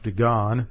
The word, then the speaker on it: Dagon: So when the Philistines took the Ark of God, they brought it into the house of Dagon, the temple of, actually should be pronounced Dagon, to the temple of Dagon, and they set it by Dagon.